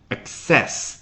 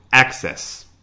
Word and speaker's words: access: access 做动词讲，它的重音是 access 第二个音节。那做名词直接放在开头 access。